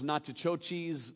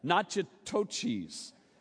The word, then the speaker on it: Natchitoches: Well, it's awfully close to that. Natchitoches, right? Sounds like nachos kind of mixed up.